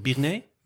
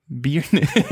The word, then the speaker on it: Byrne: En daar lag hij op de kamer met keeper Nicky Beern. Beern? Beern? En dan denk je... – Hoe zou jij dat uitspreken? Byrne? Byrne.